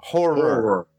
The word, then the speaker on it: horror: Oh, horror. Two syllables. Horror.